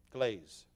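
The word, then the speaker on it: Gleyze: And Selma is her name, and Glaze, Selma Glaze. And so, but don't spell it like Glaze. It's G-L-E-Y-Z-E. Just like gray, it's glaze.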